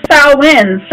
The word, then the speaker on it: Samhain: Well, I mean, it's today is Samhain, so the veil is really thin right now, and I wonder how that's going to affect him being processed on the other side. – I'm so glad somebody said that right.